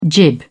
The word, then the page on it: jib: British English